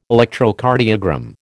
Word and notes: Electrocardiogram: American English

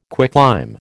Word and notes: quicklime: American English

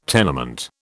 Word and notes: tenement: British English

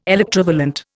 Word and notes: electrovalent: Indian

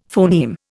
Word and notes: phoneme: Indian

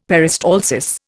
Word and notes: peristalsis: Indian

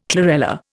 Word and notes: chlorella: Indian

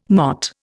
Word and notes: MOT: Indian

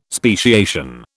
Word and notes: speciation: British English

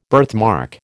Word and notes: birthmark: American English

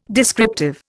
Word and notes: descriptive: Indian

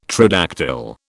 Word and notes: tridactyl: British English

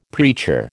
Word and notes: preacher: American English

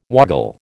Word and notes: woggle: American English

